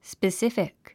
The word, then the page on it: specific: spisífik; スペスィフィック